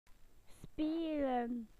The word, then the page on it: to play: British English